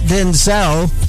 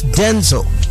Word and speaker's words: Denzel: The actual pronunciation of his name is not Denzel, it's Denzel. It should be Denzel Washington, not Denzel.